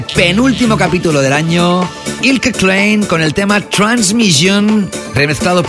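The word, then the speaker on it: Eelke Kleijn: Y te estoy hablando de este mega productor llamado Ilke Klein, así se pronuncia su nombre. En su idioma nativo, él es de los Países Bajos, aunque en inglés dicen Elke Klein. Se pronuncie como se pronuncie, yo prefiero decir Ilke Klein.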